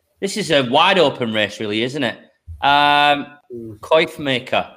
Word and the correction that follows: Coffee Maker: I think Coif, it's coffee. Maker. I think it's coffee, as in a cup of coffee, isn't it? – Coffee Maker, 4-1.